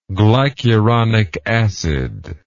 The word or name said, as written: glycuronic acid